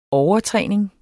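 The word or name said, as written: overtræning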